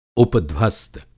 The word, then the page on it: उपध्वस्त: Hindi